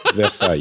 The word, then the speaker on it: Versailles: Am I saying it right? Versailles. – Versailles, yeah.